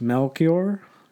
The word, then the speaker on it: Melchior: I'm going to butcher this. Melchior, Caspar, and Balthazar. – Actually, you nailed it.